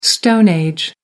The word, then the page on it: stone age: American English